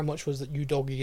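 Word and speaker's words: Udogie: Scarlett, Divine, Udogi, which, by the way, I've been pronouncing wrong. It is Udogi. – Udogi! – I thought it was Udogi. It's not. It's Udogi.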